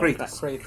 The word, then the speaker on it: Kratos: Kratos.